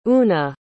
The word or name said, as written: oonagh